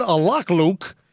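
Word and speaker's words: Alaklouk: Well, I said Alakluk. It's spelled A-L-A-K, Alak, L-O-U-K, Alakluk. Ahmed Alakluk.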